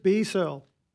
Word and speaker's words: B Searle: It's spelled B-S-E-A-R-L. So I'm like, what is it? B-S-L? But no, apparently his full government name is Brandon Searle. So it's literally just B Searle.